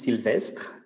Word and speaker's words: Sylvestre: Yeah, so my name is Sylvestre. That's the way you would pronounce it as a French.